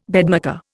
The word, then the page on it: bedmaker: Indian